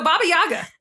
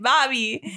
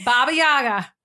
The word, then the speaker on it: Baba Yaga: No, you are saying Bobby. – Baba Yaga. – Baba. – Baba. What? What am I saying weird? – Baba. – Baba. – You're saying it correctly now.